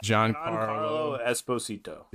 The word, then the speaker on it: Giancarlo Esposito: Giancarlo Esposito? – Yeah.